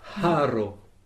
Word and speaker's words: haro: Donc, mangi s'écrit M-A-N-G, accent circonflexe, I. Passons au H. Alors, le H est, en français, on dit un H aspiré. En fait, il faut expirer pour prononcer cette lettre. Alors, par exemple, haro.